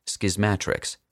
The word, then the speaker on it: Schismatrix: At last, I can formally tell a skeptical public that the title of Schismatrix is pronounced Schismatrix, with a short A, like schismatics, but with an R.